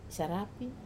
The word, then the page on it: sarap: Wamesa